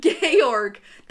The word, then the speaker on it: Georg: Georg. – Gaylord? – Georg. That's how you pronounce this.